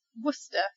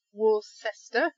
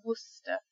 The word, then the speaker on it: Worcester: Okay, number three is this group of letters C-E-S-T-E-R. So if you see this, you just pronounce the S-T-E-R, so it's a st sound. So we've got Worcester. It's not Worcester, it's Worcester.